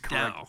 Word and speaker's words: Dow: Tony Dow. – Uh, Tony Dow. But, uh, like the, the, girl that Timberfake was with, who appeared on the show, uh, mispronounced his last name as Tony Doe.